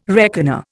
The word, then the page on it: reckoner: Indian